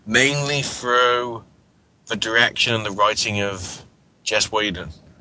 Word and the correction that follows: Joss Whedon: I never get his name right. Sean, help me out here. – Joss Whedon. – There we go.